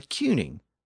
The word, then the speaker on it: cyning: And the standard French rules of pronunciation would suggest that if you put a C at the beginning of that word, C-Y-N-I-N-G, it should be pronounced as an S. But it wasn't. I mean, England was ruled by cuning, not a tuning or tuning.